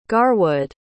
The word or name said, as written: garwood